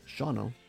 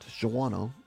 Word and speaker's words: Shawano: If you're not sure where it's at, and by the way, it's pronounced Shawano, not Shawano, as so many people say.